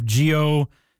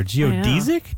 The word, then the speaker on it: geodesic: And if you're wondering about the pronunciation of geodesic, I love it. – That's the thing that caught my ear. Geodesic? Geodesic? Apparently, according to the Merriam-Webster dictionary, both are acceptable.